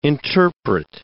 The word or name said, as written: interpret